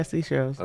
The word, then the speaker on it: Seychelles: Yeah, seashells.